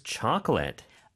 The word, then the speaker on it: chocolate: Of course, chocolate. – Of course. – Chocolate. It looks like chocolate. Chocolate. – 맞아요. 조금 late 같은 느낌이죠. 그렇죠? 초코, C-H-O-C-O-L-A-T-E.